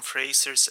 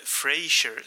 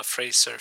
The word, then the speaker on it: Fraser: inte Frasier som jag av lite oklar anledning hade fått för mig.